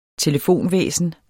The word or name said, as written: telefonvæsen